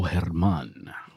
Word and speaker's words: Germán: Why don't you tell us how to say this guy's name, you know, properly? – Is she 90? – I said, sure, Betty, and your blue hair. And I said, sure. His name is Domingo Germán.